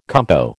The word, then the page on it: compo: American English